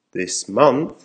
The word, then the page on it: this month: American English